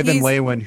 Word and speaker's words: Van Leeuwen: Troy Van Nuenen. I don't know how to pronounce his name, but yeah, Troy Van Leeuwen, who played in Failure.